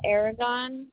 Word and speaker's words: Aragon: Ursula Aragon.